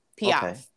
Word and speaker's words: Piaf: Piaf. Piaf. – Spell that last name. – P-I-A-F.